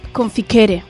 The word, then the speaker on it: conficere: Proviene del latín confirense, ¿no? ¿Se dice así? – Conficere. – Conficere.